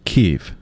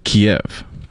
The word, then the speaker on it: Kyiv: The breakdown on that is in Ukrainian. – Okay. – It is pronounced Kyiv. – Okay. – In Russian, it is Kiev.